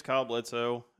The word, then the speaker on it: Kyle Bledsoe: My name's Kyle Bledsoe.